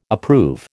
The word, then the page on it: approve: American English